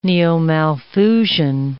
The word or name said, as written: neo-Malthusian